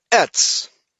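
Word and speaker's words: ets (עץ): Sorry to interrupt here, but I'm just looking up the meaning, the Hebrew word in Genesis 3:6 for tree, and it's ets, E-T-S, that's the pronunciation.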